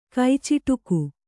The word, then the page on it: ಕೈಚಿಟುಕು: kannada